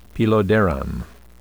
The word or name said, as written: peloderan